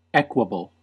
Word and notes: equable: American English

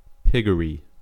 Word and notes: piggery: American English

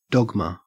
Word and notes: dogma: Australian English

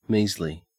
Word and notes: measly: Australian English

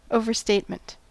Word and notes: overstatement: American English

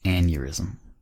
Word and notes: aneurysm: American English

